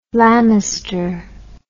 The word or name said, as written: lammister